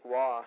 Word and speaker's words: Roy: I mean, even like a guy like Patrick Waugh. I mean, it's not even spelled that way. It's spelled R-O-Y. Who would have thought it would sound like Waugh? But it's French, so that's how it's said.